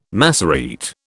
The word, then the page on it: Massorete: British English